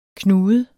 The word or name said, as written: knude